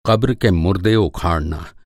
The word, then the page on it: क़ब्र: قبر